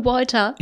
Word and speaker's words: Walter: Hank wird zu Hause zu Marie sagen, Hank, Marie und Walter, Jesper, die Namen werden englisch ausgesprochen. Es ist Marie und Walter.